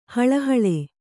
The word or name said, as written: ಹಳಹಳಿ